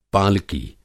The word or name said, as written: पालकी